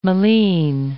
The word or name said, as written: moline